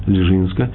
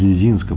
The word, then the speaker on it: Лежинск: И, кстати, он был отцом известнейших двух мудрецов и праведников, раби Элимелаха из Лежинска, или из Лезинска, по-разному произносится, Лежинск, и раби Зуси из Анаполя.